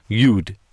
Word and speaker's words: yod: It's called the yod or yud, depending on how you pronounce it.